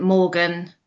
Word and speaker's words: Morgan: Joe, so that's JO, and then Morgan, MORGAN.